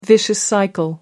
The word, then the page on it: static electricity: British English